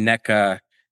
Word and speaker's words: Nneka: So if you're pronouncing Nneka, N-E-K-A, that means the letter E in every scenario should be pronounced eh. Across the board, okay.